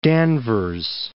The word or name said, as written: Danvers